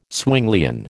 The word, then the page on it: Zwinglian: American English